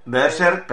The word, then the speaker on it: Berserk: Berserk, perfecto. – Explicar. – Es maravilloso. – Berserk.